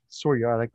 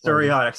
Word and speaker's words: psoriatic: You have psoriatic arthritis. Is that, am I saying that right? – Psoriatic arthritis. – Psoriatic, sorry.